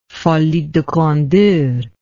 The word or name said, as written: folie de grandeur